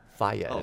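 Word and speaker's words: Fage: I think I got that off the back of my Faj yogurt or whatever it is. – Someone actually. – Faya. Is the way to pronounce that.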